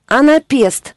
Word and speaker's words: анапест: Анапест. Ударение на последний.